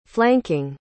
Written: flanking

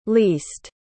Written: leased

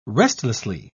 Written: restlessly

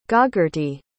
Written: gogarty

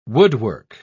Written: woodwork

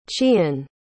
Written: chien

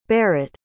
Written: barrett

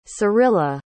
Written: cirilla

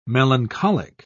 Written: melancholic

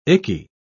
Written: icky

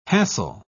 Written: hassle

